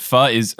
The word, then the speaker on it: pho: Pho is the correct pronunciation, but I do love that pho places make faux puns when they do it.